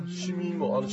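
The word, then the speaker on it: 凍み芋: シミ芋、あのシミ豆腐と一緒だよ、うんうん、凍るに実って書いてあるの、シミって読むんですね。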